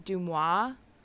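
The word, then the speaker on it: DeuxMoi: I believe it's pronounced, like, Dumois. It's D-E-U-X-M-O-I.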